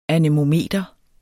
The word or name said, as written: anemometer